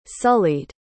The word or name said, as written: sullied